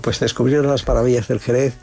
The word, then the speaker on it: Jerez: Pues en aquella época la palabra Jerez, escrita también con X, no la pronunciamos como tú y yo ahora. Jerez, no. Decían sherry.